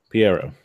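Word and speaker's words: Pierrot: Him and the studio, which I believe is Parrot, wanted to experiment. – Piero. – Thank you. I've been wondering how to pronounce that for a long time.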